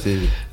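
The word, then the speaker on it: Semi: Если я себя правильно помню, Семи все-таки читается. – Семи.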